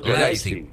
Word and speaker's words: Leipzig: Leipzig, ¿cómo se dice? – Leipzig. Con A.